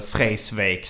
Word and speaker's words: Vreeswijk: Jag känner lite som, jag läste en biografibok om Cornelis Vreeswijk. Eller Freisvik som han själv uttalade.